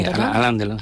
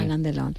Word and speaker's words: Alain Delon: ¿Lo estoy pronunciando bien? – Sí, Alain Delon. Sí. – Alain Delon.